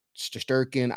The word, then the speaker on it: Shesterkin: I'm talking about Shesterkin.